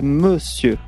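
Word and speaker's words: Môsieur: Alors, c'est Monsieur. – Monsieur, parce que oui. Il s'écrit d'une façon particulière. – Voilà, comme Monsieur, mais sans le N et avec un circonflexe sur le O.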